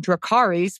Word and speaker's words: Dracarys: You can look up House of the Dragon Dracarys with emphasis on the AR because it's an augmented reality dragon.